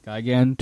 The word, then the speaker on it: Gigan: Gigan to assist them.